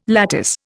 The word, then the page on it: lattice: Indian